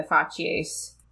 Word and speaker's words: fasces: The phrase or the story behind the fasces was... – is that how you say it? – Something like that. Fasces, yeah.